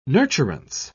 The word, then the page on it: nurturance: British English